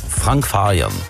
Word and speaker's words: Frank Farian: Frank Farian. Misschien een beetje op zijn Duits uitspreken.